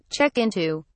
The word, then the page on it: check into: British English